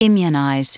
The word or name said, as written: immunize